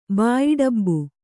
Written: ಬಾಯಿಡಬ್ಬು

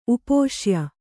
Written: ಉಪಶಾಯ

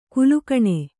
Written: ಕಾಲುಗುಣ

